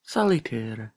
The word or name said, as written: salitir